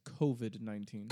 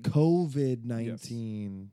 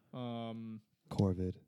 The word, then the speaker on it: COVID-19: I was pronouncing it wrong. I added an R for, I guess, phonetic reasons because my brain thought it sounded better. It's not COVID-19, it's COVID-19. – COVID-19.